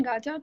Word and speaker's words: Gaja: Um, and we have with us Chintan Gaja today.